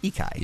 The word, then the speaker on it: Ikai: I think this is pronounced, and you could correct me if I'm wrong, Ikai? Something like that? – Ikai. Yeah. – Ikai. We'll go with Ikai.